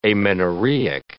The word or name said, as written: amenorrheic